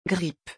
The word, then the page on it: grippe: French